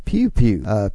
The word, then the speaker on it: Pew Pew: so she told me it's pronounced Pew Pew. Pew Pew is how you pronounce it. She said if local sometimes call it P10.